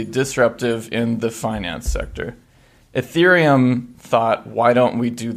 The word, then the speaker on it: Ethereum: In the last 6 months, there's been a lot of talk about this thing called Ethereum. Am I saying that right? – Yes, you are. – Okay, Ethereum.